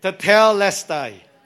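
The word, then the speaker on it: Tetelestai (τετέλεσται): Tetelestai. It is the correct pronunciation because I asked Google.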